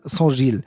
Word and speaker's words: Union Saint-Gilloise: Das ist nicht so schlimm, aber ist ja ein spannender Verein Union Saint-Gilloise. Oder einfach, du kannst auch nur den Verein aussprechen, also Saint-Gilles. Entweder Saint-Gilles, das geht auch, oder Union Saint-Gilloise.